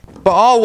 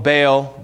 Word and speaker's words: Baal: Baal was a weather god. A lot of times we call him Baal, but the proper way to say is Baal.